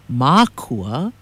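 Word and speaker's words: mākua: Makua, with a kahako or a makron over the first A, pronounced makua, is the plural of makua, or parents.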